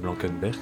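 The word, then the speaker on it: Blankenberge: Tu le prononces bien, c'est un peu à la française. – Oui, je suis pas très, très forte en néerlandais. – Non mais c'est Blankenberg, c'est très bien, on t'a bien compris.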